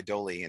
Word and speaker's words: Doley: It's L-A-C-H-Y is his first name, pronounced Lucky, D-O-L-E-Y, Dolly, and the Australian blues rock guy.